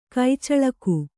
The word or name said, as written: ಕಚ್ಚಲಿಗೆ